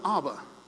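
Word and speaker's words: Ab (אב): In ancient Hebrew, the word Abba wasn't Abba. It was Ab. Simply A-B. Pronounced Ab.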